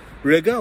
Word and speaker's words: Riga: So as you guys know, I'm out here in Riga, which rhymes with nigga.